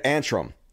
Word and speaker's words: Antrim: Yeah, Antrim.